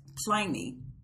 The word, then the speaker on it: Pliny: The beer, however, is pronounced Pliny, but it's spelled Pliny.